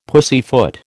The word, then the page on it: pussyfoot: American English